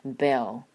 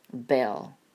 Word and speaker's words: bell: 首先我们来看单词叫 footing， footing 这个的发音，然后 entire bell bell 这个然后加上这个 dark l 的发音 bell bell。